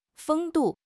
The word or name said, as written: 风度